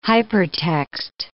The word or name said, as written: hypertext